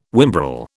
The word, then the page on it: whimbrel: British English